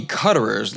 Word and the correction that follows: Cutrer: last name is actually pronounced Cutrer or Coutrer.